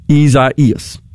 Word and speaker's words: Isaias: Isaias. The I pronounced is E, which is actually a short I, but it's pronounced E-E-E, phonetically.